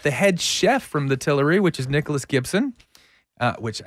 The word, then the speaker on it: Tillery: You know, but hey, so today we have special guests on from the Tillery Kitchen and Bar. Is that the correct way to say it? – That is correct.